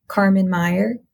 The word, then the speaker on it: Karman Meyer: My name is spelled differently, but you can find me at Carmen Meyer, K-A-R-M-A-N-M-E-Y-E-R.